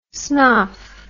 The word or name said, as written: snoff